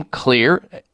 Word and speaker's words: Xlear: This is safe, and one of the lead ingredients in it is xylitol, and that's why the name Clear. Clear is spelled X, as in the case of xylitol, X-L-E-A-R, and their rescue formulation is incredible, containing oregano, tea tree, parsley, patiarco, and grapefruit seed extract.